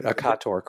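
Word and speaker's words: Occator: And I hope I'm pronouncing it correctly. I should remember Akator Crater, which is where the brightest of these spots is, the ones that have gotten the most attention. – And right, Akator Crater.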